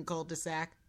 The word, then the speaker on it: cul-de-sac: How many T's are in what you are saying? – Cul-de-sac? – Yeah. How do you spell that? – More T's than there should be. there no I know T's. There's no T's in cul-de-sac. – Hey, you said it right.